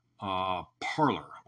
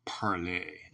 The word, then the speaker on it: Parler: And follow me, on Parler, or Parlay, as it's properly pronounced.